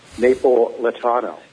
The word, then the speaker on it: Napolitano: Napolitano. – Yeah, anyway, Napolitano. Kind of like Neapolitan ice cream, only different.